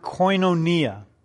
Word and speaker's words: koinonia: koinonia. Koinonia, let me spell it for you in English. It's K-O-I-N-O-N-I-A, koinonia.